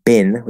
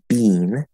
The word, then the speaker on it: been: Um, been which in American English is most people pronounce that bin with the second vowel sound. Canadian English, well, some Canadian English and British English pronounce it bean with the first vowel sound, um, but we pronounce it bin.